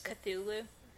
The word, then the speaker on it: Cthulhu: Cthulhu. – Cthulhu. – Was it Cthulhu? – Yeah. Let me see it. – I remember thinking it's not how it's spelled, in my opinion.